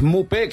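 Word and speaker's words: Maupay: Pregunto cómo lleva el Sevilla lo de Mupé, que creo que se dice así. Buenas tardes. – ¿Qué tal? Muy buenas. Mupé, que así se pronuncia, ya está en Sevilla.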